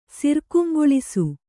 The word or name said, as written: ಸಿರ್ಕುಂಗೊಳಿಸು